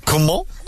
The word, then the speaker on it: Coman: Comment? – He went full blooded for that French pronunciation, in fairness to him.